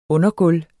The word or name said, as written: undergulv